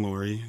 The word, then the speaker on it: Loory: My name is Ben Loory. It's spelled kind of funny. It's L-O-O-R-Y.